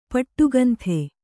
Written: ಪಟ್ಟುಕಂಥೆ

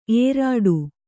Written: ಏರಿಡು

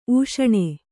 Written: ಊಷಣೆ

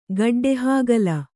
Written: ಗಡ್ಡೆಹಾಗಲ

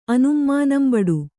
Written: ಅನುಂಮಾನಂಬಡು

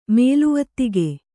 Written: ಮೇಲುವತ್ತಿಗೆ

